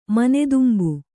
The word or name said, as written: ಮನೆದುಂಬು